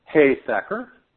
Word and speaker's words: Heisacker: Heisacker, like H-A-Y-Sacker. – H-A-Y.